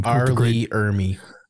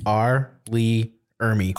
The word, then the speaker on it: R. Lee Ermey: R. Lee Ermey. – Yeah, say that again. – R. Lee Ermey.